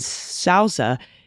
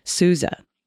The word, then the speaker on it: Sousa: Perhaps the mispronunciation of the name of the March King was humorous, but I wanted to report the OU in Sousa is pronounced like you, like Suze. Okay, Sousa.